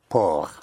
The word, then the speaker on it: port: How would you pronounce P-O-R-T? – P. – P-O-R-T. – Poor. – Yes, say it again. – Poor. – Por, right. So again, the consonant at the end of a word is not sounded unless it is followed by an E. So por, P-O-R-T, por.